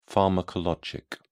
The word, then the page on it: pharmacologic: British English